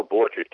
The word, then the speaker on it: Borchert: You spell Borchert, capital B for boy, O-R-C-H-E-R-T. – Right. – You pronounce it like an orchard with a B in front of it, but it's B-O-R-C-H-E-R-T, William.